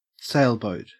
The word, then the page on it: sailboat: Australian English